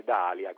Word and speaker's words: D'Alia: Un altro, per esempio, il ministro D'Alia, che degli affari regionali, che verrebbe da pronunciare Dalia, come il fiore, in realtà nella zona occidentale della Sicilia è proprio Dalia, perché deriva dal nome Alia, che è un comune nel palermitano.